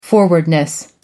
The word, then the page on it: forwardness: American English